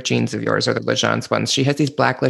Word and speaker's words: L'Agence: And let me tell you, I love L'Agence. Am I saying it right? – L'Agence. – L'Agence. Sorry.